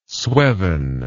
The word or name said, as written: sweven